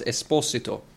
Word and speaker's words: Esposito: Får jag bara flika in där för både dig och våra lyssnare att hans namn uttalas Esposito.